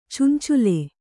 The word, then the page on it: ಚಂಚಳ: cancaḷa